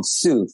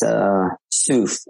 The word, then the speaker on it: Tsuph: So our brand is called Soof. It's spelled T-S-U-P-H. It's Soof. S, like pronounced like S-O-O-H. – Okay. So the T isn't pronounced.